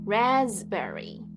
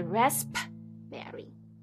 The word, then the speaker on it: raspberry: 这个单字呢要注意，它的 P 在这个单字里面是不发音的，所以我们念 raspberry 不是 raspberry，这个 P 是不发音的哦。